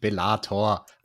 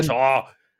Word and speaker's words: Bellator: Es heißt Bellator, nicht Bellator. Alex, Mann, ah, wir müssen Bellator.